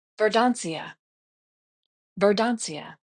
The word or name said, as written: Verdancia